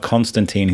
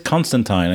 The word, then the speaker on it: Constantine: He says Constantine, and he's like, it's not like a tomato thing. He goes, no, there was one thing where he says it rhymes with Clementine.